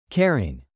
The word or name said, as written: caring